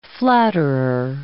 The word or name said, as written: flatterer